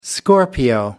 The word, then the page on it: scorpio: American English